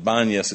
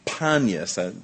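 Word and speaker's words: Paneas: but the Arabs can't pronounce a P, so they call it Banyas. It's really Panyas, as in the god Pan.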